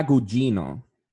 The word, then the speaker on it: Gugino: it's, the, it's the Carla Carla Gugino.